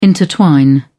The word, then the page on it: intertwine: British English